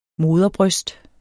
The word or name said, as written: moderbryst